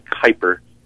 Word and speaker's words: Kuiper: My last name is spelled K-U-I-P-E-R. It's Ed Kuiper, K-U-I-P-E-R, in Amazon.